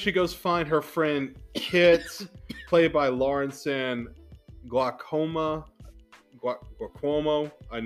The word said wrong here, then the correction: Giacomo: It's like G-I-A-C-O-M-O. You try pronouncing it. – Giacomo? – That. Yeah, we'll go with that.